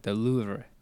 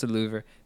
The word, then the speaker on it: Louvre: And then the French, they said the Louvre. – Okay. – It's a very soft R. But I pronounce the Louvre because you just French. – Gotta remind people that R is there.